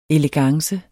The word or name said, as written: elegance